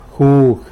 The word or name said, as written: jug